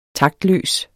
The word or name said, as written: taktløs